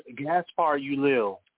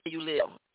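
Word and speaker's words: Gaspard Ulliel: Gaspar Ulil. – Yeah, Ulil.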